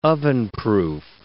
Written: ovenproof

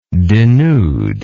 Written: denuder

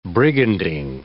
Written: brigandine

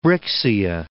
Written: Brixia

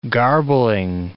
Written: garbling

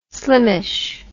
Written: slimmish

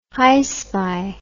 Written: hyspy

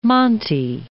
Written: monte

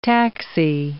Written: taxi

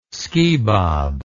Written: skibob

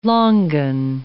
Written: longan

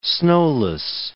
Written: snowless

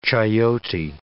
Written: chayote

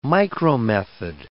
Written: micromethod

